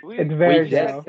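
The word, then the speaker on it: Varejão: I don't know how you pronounce it. Whatever. – It's Varejao. – It's Varejao. Oh, okay.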